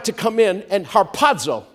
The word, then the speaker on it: harpazo: It's the word harpazo. I love just saying that word. Harpazo. With a sharp P puff. Everybody, harpazo. Accent on the second syllable.